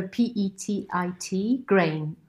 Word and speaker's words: petitgrain: Petitgrain, Yeah. So um, petty. So P E T I T grain.